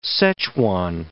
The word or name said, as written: Szechuan